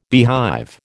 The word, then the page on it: beehive: American English